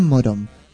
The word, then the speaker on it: Morón: El tren de línea Sarmiento bajando en Ituzaingó y tomar el colectivo 441 Cartel Verde en Morón. No se suspende por lluvia. Lo dije bien, Morón. – Excelente.